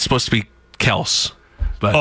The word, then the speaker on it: Kelce: Because Jason Kelce says, pronounce it Kels.